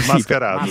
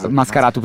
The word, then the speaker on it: Mascarato: E Vascarato. – Mascarato, sì. – Mascarato, perdonate, esatto.